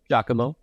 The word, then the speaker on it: Giacomo: Is that how you say his name? – Giacomo, Giacomo, yep.